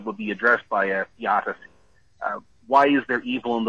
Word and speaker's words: theodicy: A theodicy, I actually believe it's pronounced theodicy, T-H-E-O-D-I-C-Y.